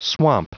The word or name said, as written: swamp